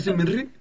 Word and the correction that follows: ASMR: Sí. Ay, ASMR. – Pues, ¿cómo se dice entonces? No, sí. ASMR.